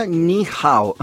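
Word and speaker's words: Ni Hao (你好): Hola, se escribe. Y se pronuncia Ni Hao.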